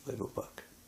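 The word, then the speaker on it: Flevopark: Flevo Park. Is that how you pronounce it? Flevo Park. – Correct.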